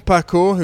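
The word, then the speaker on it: Paquot: Tom Pacour, who we think should be pronounced in the French way, of Intermarché, and Callum Scotson of Jayco Alula, Should also remind you of the general classification going into today's stage.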